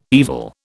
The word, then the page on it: evil: British English